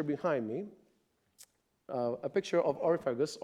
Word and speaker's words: Areopagus: How do you say it, Pastor Ken? Areopagus, thank you. That's how they say it in English. Okay, Areopagus.